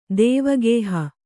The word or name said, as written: ದೇವಗೇಹ